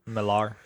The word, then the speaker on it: Millar: Millar.